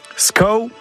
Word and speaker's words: skav: Skau, bon, ça s'écrit S-K-A-V, c'est comme ça.